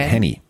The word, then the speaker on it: Henne: die Chiefs ohne Pat Mahomes, Chet, wie spricht man den aus? Henne? – Chet Henny.